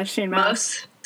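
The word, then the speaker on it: Shane Mauss: Well, Shane Mouse, is that how you pronounce his name? – Yeah, Shane Mouse.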